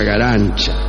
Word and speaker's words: Garanča: Se escribe Garanca, pero se pronuncia, para su desgracia, Garanča.